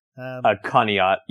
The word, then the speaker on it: Conneaut: And this is the story of the Lady in White, or Elizabeth, from the Conet Lake Hotel. The Hotel Conet, called, and maybe I'm pronouncing that wrong. – Conneaut, yeah.